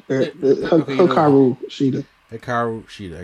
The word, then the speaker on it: Hikaru Shida: I think her next opponent will probably be Serena Deeb after she's done with her Hikaru Shida. Did I say it right? – Pretty much. Hikaru Shida.